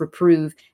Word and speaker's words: reprove: Oh, let not fools in me thy works reprove, and scorning say, see what it is to love, which either reprove or louve. Like, what's the correct pronunciation to get that rhyme? – The pronunciation of both those syllables is "ove." I love.